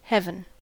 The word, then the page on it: heaven: American English